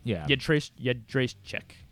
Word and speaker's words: Jędrzejczyk: Yedrzejczyk. Joanna J. – Suggested that. So apparently she's telling people that she's out of WWE before WrestleMania next year. And Joanna Jędrzejczyk thinks that she would get an immediate title shot at bantamweight.